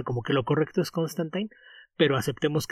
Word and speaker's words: Constantine: el mostrar que Constantine se irritara por la forma en la que pronunciaban su apellido los estadounidenses. Incluso lo escribía con la terminación como si fuera adolescente, Constantine, y él corregía que no, que lo suyo rima con vino y pino, Constantine.